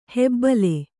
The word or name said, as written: ಹೆಬ್ಬುಲಿ